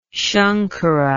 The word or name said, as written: shankara